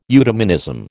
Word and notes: eudemonism: American English

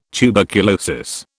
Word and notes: tuberculosis: British English

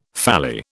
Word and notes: phalli: British English

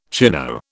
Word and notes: chino: British English

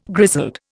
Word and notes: grizzled: Indian

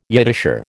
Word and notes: Yiddisher: American English

